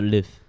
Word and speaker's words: lift: 哦，我们我们我们我们如我们如果比如美式发音，哦，我们就是 lift。